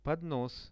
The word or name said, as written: podnos